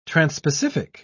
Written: transpacific

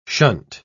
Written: shunt

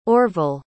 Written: orval

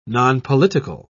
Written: nonpolitical

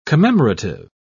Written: commemorative

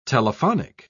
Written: telephonic

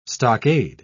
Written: stockade

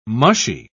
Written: mushy

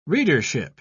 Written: readership

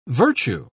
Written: virtue

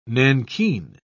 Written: nankeen

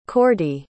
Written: Cordie

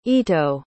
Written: ito

